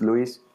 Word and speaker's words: Luis: So my name, if you say it in Spanish, is Luis Villota.